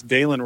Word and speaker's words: Dylan: It's spelled D-Y-L-A-N. So you think Dylan Wright. It's Dalen, something I was corrected of. So it's Dalen Wright.